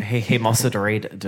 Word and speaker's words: hemosiderin: converted into something called hemosiridion. No, I've definitely said that wrong. Hemosiderin? Oh, no, this is why... – Hemosiderin.